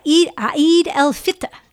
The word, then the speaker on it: Eid al-Fitr: Yeah, Eid al-Fitr.